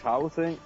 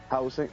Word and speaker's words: Hausen: Bueno, mi nombre es Hausen, se escribe con H, así como suena, Hausen. Hausen, entonces la canción ya se encuentra en todas las plataformas digitales como Spotify, iTunes, Amazon, Deezer y demás.